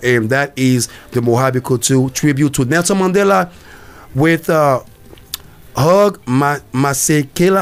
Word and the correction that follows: Hugh Masekela: I hope I said it right. – Hugh Masekela. Yeah.